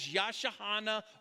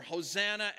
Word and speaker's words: Hosanna: Take those, squeeze them together, and what you got is Yashahana or Hosanna, as the Greeks pronounce it, which again means save us.